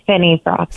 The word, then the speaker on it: Finnefrock: So, Amanda, say your last name for us again. – It's Finneyfrock. – Finneyfrock. I was saying Finney. So, Finneyfrock.